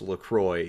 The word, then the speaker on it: LaCroix: So on their nutritional facts, the first fucking FAQ is, how is LaCroix pronounced? LaCroix, it rhymes with enjoy.